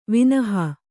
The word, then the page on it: ವಿನಹಾ: kannada